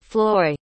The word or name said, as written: floy